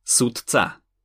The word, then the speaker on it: sudca: Pred neznelými spoluhláskami je to napríklad v slove sudca, D je znelá spoluhláska, C je však neznelá a tak D prečítame ako T, sudca.